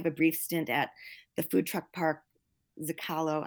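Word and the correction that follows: Zócalo: I hope I'm not saying that wrong. If I am, sorry. – I don't know how it's pronounced either, and that's the best Zocalo? – I've heard. – Zocalo? Yes. – Yeah. – Oh, what is it? – Zocalo. – Zocalo. Yeah. Well, I was way off.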